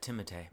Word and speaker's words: Timothée: And I'm a fan of Timothée. Is that how you say his name? Timothée? – Yeah, Timothée.